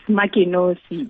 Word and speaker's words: Makenkosi: Good afternoon, Michael. It's Makenkosi. – Oh, it's Makenkosi. Makenkosi, I beg your pardon, I was going to confirm your pronunciation, so I can, going forward, call you Makenkosi.